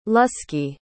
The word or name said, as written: lusky